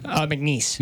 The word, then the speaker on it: McNeese: And then on Wednesday, they've got, I'm going to say this wrong, McKenzie State? – McNeese. – McNeese, I knew I was going to butcher that. McNeese State.